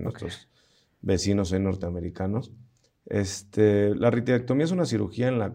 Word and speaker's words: ritidectomía: Oye, hace rato mencionabas la parte de ritidectomía, ¿lo dije bien? – Sí, sí.